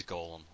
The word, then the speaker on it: Golem: It is Golem. There's like three different pronunciations they said, and there's one that's wrong. So let's go with Golem.